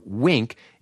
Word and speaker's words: Winc: Wink, and it is spelled W-I-N-C, is a revolutionary new wine club that takes the guesswork out of choosing your wine.